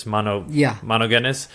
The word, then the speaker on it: monogenes: I can't pronounce either. It's monogenes. And